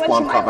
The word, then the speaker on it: Blomkamp: Blomkin? – Don't say that word. – I don't think it's Blomkamp. – I think it's Blomkamp. I think it's closer to that.